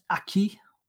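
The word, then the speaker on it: acquis: There's The Aquis is 90,000 pages. I would actually I think you'll find it's pronounced Aki.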